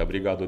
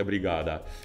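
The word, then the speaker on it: obrigada: я когда был в Бразилии, я своих двух друзей и сказал, что, ребята, а бригаду это неправильно, правильно говорить, а бригада. Так, чтобы последний звук был четко слышен.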